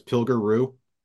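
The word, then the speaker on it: Pilger Ruh: I think I have the pronunciation right, but it's Pilger Roo. – Yes, correct.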